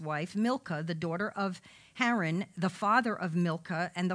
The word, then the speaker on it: Haran: Terah begat Abram, Nahor, and Haran. Actually, I think that's pronounced Haran. I'm sorry, Haran.